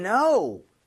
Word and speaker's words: know: How did you know? 알다, 알고 있다라고 하는 동사죠. 뭐 당연히 K는 소리가 나요, 묵음이요? – 묵음이죠. – 묵음이죠. Know는 말도 안 되죠.